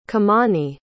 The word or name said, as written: Kumani